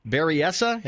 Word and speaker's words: Berryessa: Berryessa, have I got that close? – Yeah, you got it right. – Berryessa Valley Church. Maybe I need to work on my own self-confidence here. Berryessa Valley Church, San Jose, California.